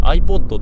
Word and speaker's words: iPod: あの当時ね iPod って読めなかったんです、 iPad っていう風に読んでてあの O ってさ、 A って読むじゃないですか、あの A の音をするじゃないですか、なんて言ったらいいんだろうな、あのなんか英語の発音で、 O ってあって読むことがあるじゃないですか。